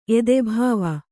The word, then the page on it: ಎದೆಭಾವ: edebhā va